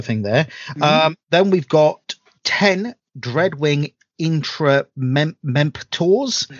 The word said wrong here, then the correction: Interemptors: Oh, the interemptors. – The interemptors. Yeah. Okay, that's how you pronounce it.